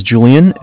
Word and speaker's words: Julian: My name is Julian. It's J-U-L-I-A-N. – Okay. – Calling from Bank of Cardiff. Cardiff is spelled C-A-R, D as in David, I-F-F as in Frank.